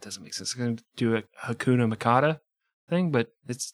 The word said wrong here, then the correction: Matata: It's also Matata. When I say Makata?